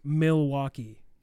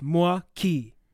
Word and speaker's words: Milwaukee: So if you hear it on the news, it's pronounced as Milwaukee.